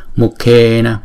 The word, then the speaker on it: mukhe: Chữ na và chữ me là hai nhịp, praname, praname, mukhe, mukhe. Chữ k đứng một mình chúng ta đọc là cờ, chữ k và chữ h chúng ta đọc là khờ, khờ, mukhe, mukhe.